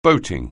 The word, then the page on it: boating: anglais